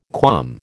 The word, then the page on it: qualm: American English